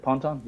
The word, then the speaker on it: Ponton: Let's talk about your third project, Ponton. I don't know how to pronounce it. How do you pronounce it? – No, that's it. Ponton.